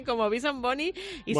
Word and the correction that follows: Benson Boone: Benson Boone.